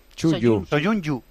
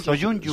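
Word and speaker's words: Söyüncü: La última Chu es Yu. – Soyunyu. ¿Cómo es entonces? – Soyunyu.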